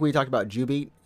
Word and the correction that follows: jubeat: Or U-Beat. – U-Beat. There we go. Yeah, you said something much worse. – Oh, my goodness. Oh, gosh. No, I didn't mean that. I didn't mean it's spelled J. – It's pronounced U-Beat.